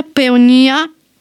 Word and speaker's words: Peonia: Peone, come si pronuncia? Peonia. – Peonia.